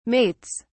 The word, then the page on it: mates: British English